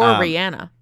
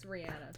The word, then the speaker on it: Rihanna: or Rihanna. It's Rihanna technically. – That's how she pronounces it herself.